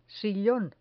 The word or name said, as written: sillon